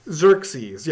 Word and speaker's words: Xerxes: Xerxes, yes.